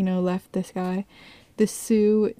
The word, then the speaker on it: Sioux: So he saw that he was a Sioux, or that's how you say it, right? – Yeah, Sioux. – Sue.